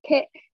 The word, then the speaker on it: Kael: K-A-E-L. So it's not Kael. It's Kael like the vegetable, but it's spelled K-A-E-L and then Honey, H-O-N-E-Y.